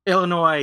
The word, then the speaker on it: Illinois: Yeah, I think he said that the S is silent in his email. – Illinois, yes.